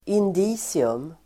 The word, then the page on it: indicium: [ind'i:sium]